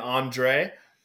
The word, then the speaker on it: Andres: I was like, oh, my name's Andre, or my friends all call me Dre.